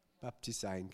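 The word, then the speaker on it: Baptisein (βαπτίζειν): Also das ist Baptisein oder Baptisein, spricht man so aus? Baptisein, genau. Also von der Bedeutung bedeutet es so tauchen, eintauchen, untertauchen, manchmal auch waschen, aber ich glaube in Wirklichkeit geht es um dieses Tauchen.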